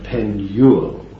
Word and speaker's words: Penuel: That's the way to pronounce it. Emphasis on the middle word, the middle letter, Penuel.